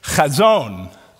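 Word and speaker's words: chazon (חזון): You're going to pronounce this with me. It's chazon. Chazon. Get that spit.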